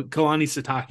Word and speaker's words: Kalani Sitake: Yeah, no, Kalani Sitake. You had it perfect.